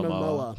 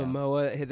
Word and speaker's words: Momoa: Yeah, how do you say his last name? – Jason Momoa. – Yeah. Momoa.